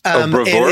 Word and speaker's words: bravura: Brauva. It's something like that. – Bravura? It's this. – Is that how it's pronounced? – I'm pretty sure you're meaning bravura.